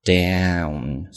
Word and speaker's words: down: 相反，上面是一个双元音，读作 down。